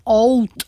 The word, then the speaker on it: oud: Teď tady k těmhle dvěma přistoupila a se připojila Vaed Bou Hasoun, což je syrská zpěvačka a hráčka na oud, což je, nebo správně se asi vyslovuje oud, což je blízkovýchodní typ loutny s takovým měkkým, temným zvukem.